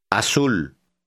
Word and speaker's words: azul: Azul. – Notice that the letter Z is pronounced just like the letter S in Spanish, never like our Z.